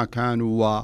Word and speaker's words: كانوا: شف لاحظ في, في, في نفس الكلمة التي في الآية 199 في قوله تعالى فقرأه عليهم ما كانوا, لاحظ الألف اللي تبعد الواو ما تقول ما كانوا و لا تنطقها لا وصلا ولا وقفا ما كانوا به ولا كأنك انظر إليها.